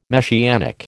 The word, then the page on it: Messianic: American English